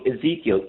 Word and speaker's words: Ezekiel: Maybe I'm pronouncing the name wrong. Ezekiel, E-Z-E-I. – Yeah, no, you're not pronouncing it wrong, but it's also a bread in the freezer of the fancy supermarkets.